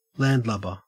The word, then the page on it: landlubber: Australian English